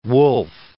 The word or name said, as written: Wolfe